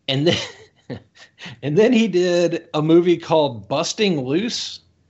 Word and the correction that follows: Bustin' Loose: from 1981. Well, I said it wrong. Bustin' Loose.